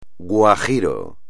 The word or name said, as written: guajiro